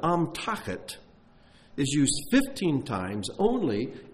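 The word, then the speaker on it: Amtachet (אמתחת): Amtachet is the word. Amtachet. It's pronounced that way.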